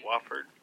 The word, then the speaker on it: Wofford: Am I even pronouncing that right? – Wofford, whatever. – Yeah, Wofford.